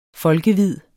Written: folkevid

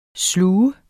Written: slugt

